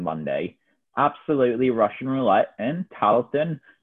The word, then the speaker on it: Tarleton: Tarleton, that's the best name. Tarleton. It's like the classic 80s, 1880s, that is, Tarleton.